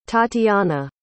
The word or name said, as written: tatyanna